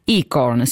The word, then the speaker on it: ICORN: ICORN, si pronuncia così, giusto? – Sì, perfettamente.